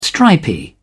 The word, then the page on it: stripy: British English